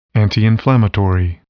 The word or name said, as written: antiinflammatory